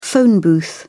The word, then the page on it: phone booth: British English